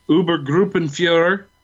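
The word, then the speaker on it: Obergruppenführer: Who had, you know, when we first meet him, he's Uber Gruppenführer.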